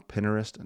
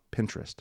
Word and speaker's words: Pinterest: Don't waste it with these silly little puns and these little jokes, the kind of things that you can look up on Pinterest. No. Pinterest? I always say it wrong. Pinterest.